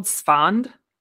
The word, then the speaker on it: Esfand: but it's called Sfond. – I think it's the ritual. – Okay. – And it's spelled E-S-F-A-N-D.